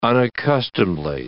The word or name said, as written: unaccustomedly